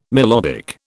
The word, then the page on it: melodic: British English